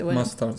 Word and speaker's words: Mustard: Mustard, ¿cómo se dice? – Mustard, sí, Mustaza.